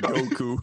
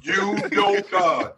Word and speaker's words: Udoka: It's pronounced exactly the way it's spelled. Udoka. – Goku. – Udoka. That's the way it's pronounced.